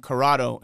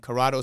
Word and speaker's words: Corrado: And it's my last name, Corrado. And Corrado is spelled CORRADO.